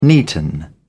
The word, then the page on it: neaten: British English